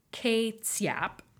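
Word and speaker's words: kê-tsiap: The Oxford English Dictionary traces ketchup's etymology to the Amoy Chinese word ke tsiap, which is pronounced K, like E with like a little circumflex accent, hyphen T-S-I-A-P. Okay. Like pretty much ketchup.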